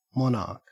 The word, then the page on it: monarch: Australian English